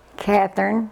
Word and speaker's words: Kathryn: Catherine, K-A-T-H-R-Y-N, Ann, A-N-N, Skelton, S-K-E-L-T-O-N.